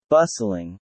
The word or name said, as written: bustling